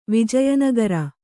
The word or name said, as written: ವಿಜಯನಗರ